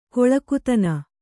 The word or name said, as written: ಕೊಳಕುತನ